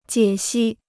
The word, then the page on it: 解析: jiě xī